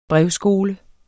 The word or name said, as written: brevskole